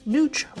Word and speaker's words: Nooch: Nooch, rhymes with pooch, is a nickname for nutritional yeast which delivers a cheesy taste but without the dairy.